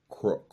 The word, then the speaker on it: CROIX: How's that pronounced? – Croy. – How is that? Crook? – Croy. – Croy. – Yes.